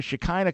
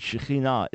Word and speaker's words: Shekinah: I'm just saying the Hebrew is Shekinah.